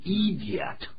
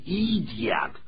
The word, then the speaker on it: idiot: 这个是变成 idiot，不是这样念 idiot，是这样念的。 – Idiot. Idiot. 第一个 I 是念作 I 短音，第二个是 E 是长音，idiot.